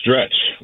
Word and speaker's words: Stretch: Second, your guy got my name wrong. It's Stretch, like Armstrong.